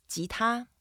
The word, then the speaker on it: 吉他: 吉 is the second tone. You go up a little bit. 吉. And 他, it's the first tone, so we keep it flat. 他. – 吉他, beautiful.